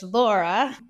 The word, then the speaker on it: Lora: I kind of stole this from you, Bianca, but it's Accent Coach Lora. And my name, L-O-R-A. – Yes, that's something I remember. Yeah, L-O-R-A, Lora.